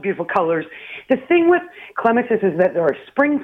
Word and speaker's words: clematis: The proper pronunciation is from the Greek, klema, to climb. So properly, they're pronounced clematis because of the clema to climb part of it.